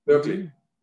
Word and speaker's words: Berkeley: And well, the sister company is in, I'm not sure how you say it, Berkeley or Barclay, near Los Angeles, Barclay, is it? – Berkeley? – Berkeley.